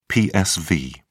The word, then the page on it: psv: British English